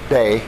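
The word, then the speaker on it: day (δέ): That little word, which is pronounced day, that little word usually is translated into English as but I say to you, okay?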